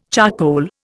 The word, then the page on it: charcoal: Indian